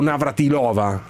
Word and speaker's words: Navratilova: Ci dica una volta per tutte, si pronuncia Navratilova o Navratilova? Risposta: in verità si dice Navratilova.